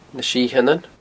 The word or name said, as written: Na Sitheanan